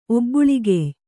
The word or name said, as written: ಒಬ್ಬುಳಿಗೆಯ್